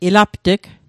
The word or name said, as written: ilapt'g